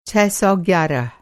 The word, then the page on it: छह सौ ग्यारह: چھ سو گیارہ